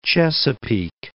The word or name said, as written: Chesapeake